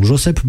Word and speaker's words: Josep: Josep Borrell, finalement on dirait que c'est comme ça qu'on prononce son prénom, Josep Borrell, qui est vice-président de la Commission européenne, lui, qu'est-ce qu'il a dit ?